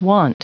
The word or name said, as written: want